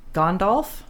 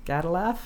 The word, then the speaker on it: Gandalf: This is the actor that played Gandalf, who when I was reading the books was Gadalaf, because I didn't know how to pronounce it. It's true. When I went to see the movies, I'm like, Gandalf? No, that's Gadalaf. Wrong.